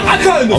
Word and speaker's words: Econ: Econ. Oui, il faut le prononcer aussi à la française, je veux dire.